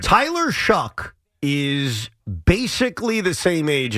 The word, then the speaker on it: Shough: And then I saw in the second round, they're talking about possibly drafting Tyler Shucks. – Yeah. – Louisville. – Yeah, he's actually gaining steam. Shuck. Even though it's spelled S-H-O-U-G-H. – It rhymes with suck.